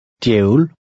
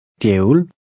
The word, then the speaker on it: djævel: In number 5, the DJ combination sounds like DY. Try to read number 5. – Djævel. Djævel.